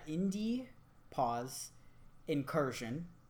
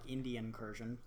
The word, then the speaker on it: Indie Pause Incursion: This is from Von Hyde, friend of the show, over at Indie Pause Incursion. I have to say it like that because otherwise it sounds like Indian Incursion, like Indian Incursion.